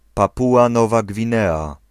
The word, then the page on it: Papua New Guinea: /ˈpæ.puː.ə ˌnjuː ˈɡɪ.ni/; /ˈpæ.pjuː.ə ˌnjuː ˈɡɪ.ni/; /ˈpæ.pu.ə ˌnu ˈɡɪ.ni/